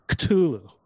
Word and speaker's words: Cthulhu: everybody pronounces it different, but I like to pronounce it like you're sneezing, kind of like Cthulhu.